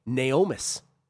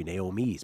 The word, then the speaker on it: Naomis: He's on the number five horse, Naomis. If you put an apostrophe there before the S, it would be Naomi's, but I believe it's pronounced Naomis, at 3-1.